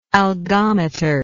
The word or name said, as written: algometer